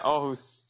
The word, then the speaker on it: Aarhus: Yeah, so you're currently back in Denmark, in your hometown of, let me pronounce it correctly, Aarhus? – Yeah, Aarhus.